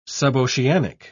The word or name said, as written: suboceanic